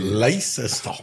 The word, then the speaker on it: Leicester: Aber Leicester ist doch gut. Also hast du perfekt ausgesprochen.